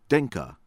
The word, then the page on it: Dinka: American English; from Sudan